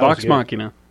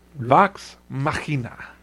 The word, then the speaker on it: Vox Machina: Vox Machina. – Vox Machina.